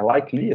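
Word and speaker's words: Lykke Li: É Likely, assim que se pronuncia? – Sim, sim.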